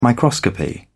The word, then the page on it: microscopy: British English